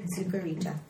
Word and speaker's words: Zucarita: It's spelled Z-U-C-A-R-I-T-A. – Could you say that again? – Z-U-C-A-R-I-T-A. Sucarita.